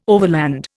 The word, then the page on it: overland: Indian